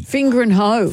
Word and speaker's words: Fingringhoe: Spare a thought for the people over in Essex at Fingering Hoe. That's the name of the town, Fingering Hoe. – How do you spell it? – F-I-N-G-I-O. – Start again. – Fingringhoe.